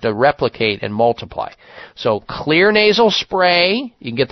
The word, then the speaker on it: Xlear: And that's spelled X-L-E-A-R, clear nasal spray.